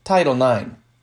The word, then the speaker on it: Title IX: Title what in the world? It's pronounced Title IX.